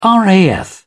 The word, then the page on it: raf: British English